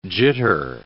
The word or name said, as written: jitter